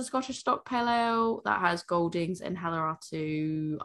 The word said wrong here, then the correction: Hallertau: I can't pronounce that second half. – Hallertau, I cannot pronounce that. I can never pronounce that. That is a fucking hard one. – Hallertau, Mittelfrüh.